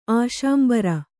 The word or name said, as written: ಆಶಾಂಬರ